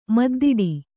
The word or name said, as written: ಮುದ್ದಾಡು